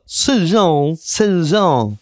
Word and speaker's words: Zhejiang: Also eine ärgerliche Frau hat in der chinesischen Provinz Zhejiang oder Zhejiang. – Genauso wird es ausgesprochen.